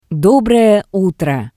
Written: Dobroe utro

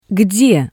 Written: gde

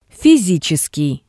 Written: fisitseskii

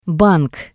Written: bank

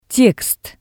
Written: tekst

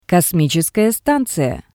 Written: kosmitseskaya stanziya

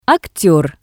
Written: akter

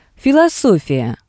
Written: filosofiya

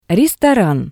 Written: restoran

